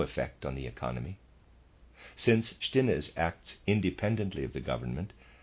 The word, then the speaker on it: Stinnes: Readers aside, Stinnes, I'm pronouncing it that way, is spelled S-T-I-N-N-E-S, Stinnes.